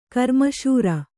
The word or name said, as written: ಗ್ರಾಮಸಾರ